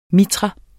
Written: MITRAEN

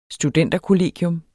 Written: studenterkollegium

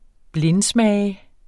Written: blindsmage

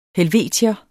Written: helvetier

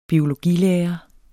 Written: biologilærer

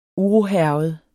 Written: urohærget

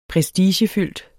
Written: prestigefyldt

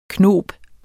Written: KNOBS

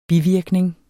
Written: bivirkning

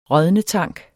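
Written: RÅDNETANK

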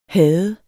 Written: hade

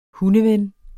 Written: hundeven

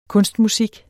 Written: kunstmusik

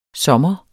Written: sommer

